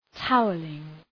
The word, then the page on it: towelling: ‘taʋəlıŋ